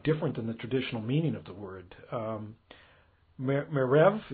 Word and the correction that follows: Merav: is that how you say it? Merev Cooperberg? – Merav, yeah.